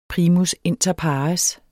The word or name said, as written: primus®